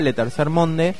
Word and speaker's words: Le Tercer Monde: @letercermonde.